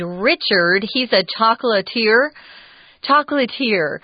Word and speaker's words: chocolatier: This is a French word, just in case you've never seen it before. I really didn't know how to say it at first. Chocolatier is also how it's pronounced, chocolatier.